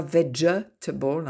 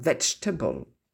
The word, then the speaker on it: vegetable: Pareil pour le deuxième E dans vegetable, on ne dit pas vegetable, hein, vegetable.